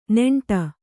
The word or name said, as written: ನೀಂಟು